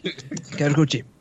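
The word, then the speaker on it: Karakuchi (辛口): Karakuk? I don't know how to say it. – Karakuchi.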